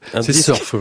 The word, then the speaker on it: Surf: Extrait de Surf Deluxe Remastered, je prononce bien ? – Oui, tout à fait. Surf, voilà.